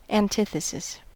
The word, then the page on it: antithesis: American English